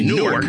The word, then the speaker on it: Newark: If you don't know what that airport code is, that is Newark. That's how the people pronounce it, Newark. We all say Newark, right. The people say Newark. We flying into Newark, and we are going to her retirement.